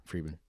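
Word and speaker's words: Fridman: Friedman. Yeah, that's how you say it. Friedman.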